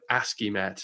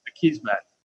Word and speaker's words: Akismet: I used to always think it was like ASCIIMet for some reason, but it's Akismet.